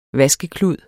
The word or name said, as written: vaskeklud